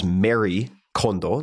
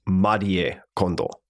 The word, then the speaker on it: Marie Kondo: It was recorded in Tokyo, where I got to meet Marie Kondo, who is sometimes referred to as Mary Kondo. So you can think of her that way if you'd like, but it is actually pronounced Marie Kondo.